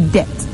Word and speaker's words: debt: Another one is whenever you find BT in a word, you do not pronounce the B. So you don't say debt, you say debt.